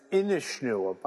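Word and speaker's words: Inis Nua: And it's Inishnewa, by the way. – Inishnewa, pardon me. I normally say it right, and then this time I didn't.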